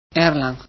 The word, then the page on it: erlang: Spanish